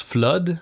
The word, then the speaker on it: Flood: Déjà, ça se prononce Flood, F-L-O-O-D.